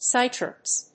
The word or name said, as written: sitreps